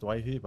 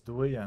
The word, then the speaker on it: Doueihi: Growing up, we were called Doueihi and Doueihi. Obviously, back in Lebanon, it's pronounced Doueihi, but I guess since I've grown up, I've always been known as, I guess, Doueihi, and we thought it was easier for the commentators to pronounce it with Doueihi as well, and my dad wants me to stick with that.